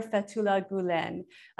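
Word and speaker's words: Fethullah Gülen: to know a little bit about that, which is the scholar Fethullah Gulen.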